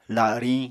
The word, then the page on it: lariñ: apatani